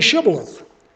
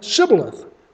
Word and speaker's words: Shibboleth: When he said no, they said to him, Then say Shibboleth. And he said, Sibboleth, for he could not pronounce it right.